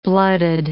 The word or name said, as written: blooded